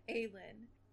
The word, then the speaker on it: Aelin: I forgot how to say her Aelin. – Name. Aelin. – It's like the letter A and yeah. Then Lin. Yeah. He took Aelin.